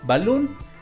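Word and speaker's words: Balún: Balloon, así como la palabra en inglés, pero se escribe tal como se escucha, con acento en la U. Balloon.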